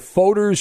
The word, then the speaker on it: Fodor's: according to Fodor's Travel. I don't know what that is. F-O-D-O-R. Fodor, I believe how it's pronounced.